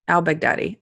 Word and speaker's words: al-Baghdadi: I wanted to say Al-Baghdad, but that sounds racist and horrible because Baghdad is just a city. And it's just, I'm adding Al in front of the city. But it is Al-Baghdadi.